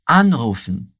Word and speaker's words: anrufen: Anrufen. Anrufen. E agora o outro. Angerufen. Angerufen. Sehr gut. Me diz aí. Conseguiu identificar qual que é a sílaba tônica? É na partícula an.